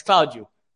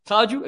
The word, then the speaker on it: Claudio: You're going to say it like you'd say it in Portuguese. And that's how I pronounce my name every day since. And it's like, it's Claudio. Claudio, if you can't say it, and okay.